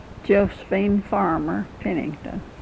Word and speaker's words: Josephine Farmer Pennington: Josephine Farmer Pennington. J-O-S-E-P-H-I-N-E. F-A-R-M-E-R, P-E-N-N-I-N-G-T-O-N.